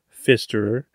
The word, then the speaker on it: Pfisterer: All right, on today's show, we've got Dominick Pfisterer.